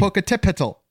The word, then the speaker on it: Popocatépetl: Popocatépetl. Like that's how you say it.